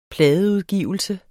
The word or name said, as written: pladeudgivelse